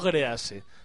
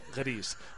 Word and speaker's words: Gris: Y por esta música podréis adivinar que el título que vamos a analizar esta semana es Gris, no Grease, sino Gris,